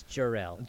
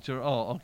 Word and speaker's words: Jor-El: We pronounce him Joel. – I'm going. Okay, Joel, that's... – We do not do that. Superman's dad or something? – No, that's Jor-El. – Jor-El. – Jor-El.